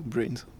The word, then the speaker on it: BRNS: En tout cas, en tant que Brains, ou on peut dire BRNS, c'est pas si grave, mais parce que ça s'écrit effectivement BRNS, mais ça se prononce Brains, voilà.